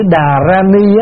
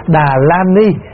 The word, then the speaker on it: Đà Ra Ni: Bởi vì tâm, cái chữ Đà Ra Ni á, nó gần hơn, vì cái chữ Phạn của nó là Đà Ra Ni, và mình đọc là Đà Ra Ni nó gần hơn, thay vì mình đọc là Đà La Ni cũng được.